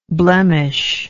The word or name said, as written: blemisher